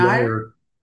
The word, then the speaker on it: Mark Aguirre: Same with you, Mark Aguirre. Is it Mark Aguirre? – Maguire. – Aguirre? – Maguire. Think right rhymes with Aguirre? – Maguire. – Okay.